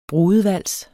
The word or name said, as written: brudevals